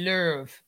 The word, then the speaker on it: Löw: And I know that Yogi Löw, you have to make sure you pronounce it with the umlaut on the last name. It's spelled low, but it's actually pronounced Löw.